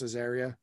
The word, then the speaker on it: Azaria: I always know, am I saying that right? – I always said Azaria. – Okay. – Might be Azaria, because like Aria is a thing, but mm-hmm. I've always ever heard it pronounced Azaria.